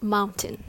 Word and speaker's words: mountain: It has two syllables. The first syllable is longer than the last one, so the rhythm goes tada, mountain. You don't have to say mountain. Rather, it's mountain. Make sure you make the last syllable really, really short.